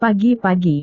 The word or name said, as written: pagi-pagi